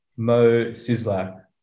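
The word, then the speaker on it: Szyslak: Simpsons character, Mo Sizlak.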